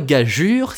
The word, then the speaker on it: gageure: Tous ces mots en geur se terminent en G-E-U-R. Par contre, gageure s'écrit G-A-G-E-U-R-E. Et cela suffit à modifier la prononciation, si si, je vous assure.